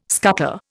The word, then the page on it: scutter: Indian